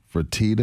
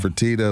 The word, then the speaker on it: Fertitta: According to Mark Stein of the New York Times, Mark, hit or miss, he's saying that the owner, Fertitta, is that how you say it? I don't want to get it wrong. Fertitta of the Rockets, he was really, really high on Jeff Van Gundy.